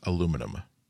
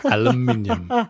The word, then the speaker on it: aluminium: And also, for international listeners, it's pronounced aluminum. – Aluminium.